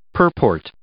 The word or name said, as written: purport